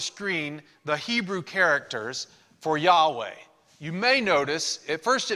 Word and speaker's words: Yahweh: Now, it also could be pronounced Jehovah Rapha. I've told you before that some people pronounce it Jehovah, some people pronounce it Yahweh. We're not sure exactly which it is, but because ancient Hebrew didn't have vowels in the name. So the sounds, some of the sounds were assumed. And many people over the years have come to pronounce it Jehovah, but most scholars think that originally it was Yahweh.